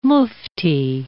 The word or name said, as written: mufti